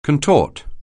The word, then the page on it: contort: anglais